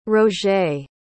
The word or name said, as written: roget